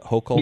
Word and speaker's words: Hochul: So, this story of Governor Hochul Am I saying her name right? Is it Hochul? Yes. Yeah.